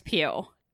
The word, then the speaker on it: Pugh: However, I want to talk to you about my new obsession, and that is Florence Pugh. And her last name is spelled P-U-G-H. I looked it up. She says Pugh, like Hugh with a P.